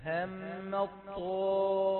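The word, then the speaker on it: همت الطائفتان: لما نقول همت هذه تاء، إذا وقفنا عليها تاء مهموسة لكنها أدغمت في الطاء فتلاشى صوت التاء وأصبحنا ننطق طاء مشددة، هم الطائفتان، هم الطاء يعني طاء مشددة تلاشى صوت التاء، فلا تحاول أن تكسر التاء تقول هم الطاء، لا هي طاء مشددة منذ البداية، دخلت التاء في الطاء إدغام فهي يعني صوت التاء يتلاشى، هم الطائفتان.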